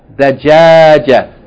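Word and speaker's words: Dajajatun (دجاجة): Da-ja-ja-tun. Tun. Dajajatun. Dajajatun. If we stop on it, how we pronounce it? – Dajaja. – Dajaja.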